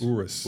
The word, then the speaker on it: Urus: So first of all, how do you say Urus or Urus? – Urus. – Urus, okay.